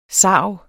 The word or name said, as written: SARGEN